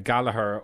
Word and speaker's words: Goligher: Now, one of the most famous of all of these was a girl named Kathleen uh, Goligher or Goligher, depending on your pronunciation. It's spelled G O L I G H E R.